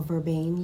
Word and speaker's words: vervain: Masculine, if you are of masculine, rosemary and vervain leaves. or verbain leaves, properly pronounced.